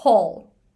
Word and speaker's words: hall: お A で ball とか、えー、 hall h-a-l-l hall っていう風にして発音してみてください。